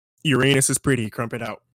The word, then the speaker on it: Uranus: So, Uranus is technically how you're supposed to pronounce it.